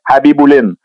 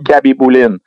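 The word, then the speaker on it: Khabibulin: Là, quand c'est KH, faut pas que tu prononces le K. C'est pour ça qu'on disait Habiboulin. On disait pas mm. Kabiboulin.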